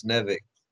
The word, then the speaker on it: Nivek: It's Kevin backwards, but it's Nevik.